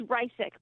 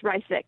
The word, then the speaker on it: Riesig: It's Riesig, but it's super hard. And it's Riesig.